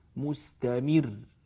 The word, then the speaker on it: مستمر: الآن سألفظ نفس كلمة مستمر هذه المشددة، سألفظها مخففة، واسمعوا الفرق. عندما أقول مثلا مستمر مستمر أو مستمر مستمر، هذا الثاني، ها النطق الثاني هذا هو النطق الصحيح، أما النطق الأول فلا يصح أبدا، ولا فرق بينهما سوى هذه الضغطة في الانتقال إلى الراء مستمر.